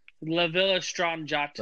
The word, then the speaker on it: La Villa Strangiato: Okay, how do they pronounce it? – La Villa Strangiato. – La Villa Strangiato. Wow. Okay. The double L definitely throws everyone for a loop.